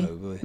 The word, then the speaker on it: Mowgli: Mowgli. – Mowgli.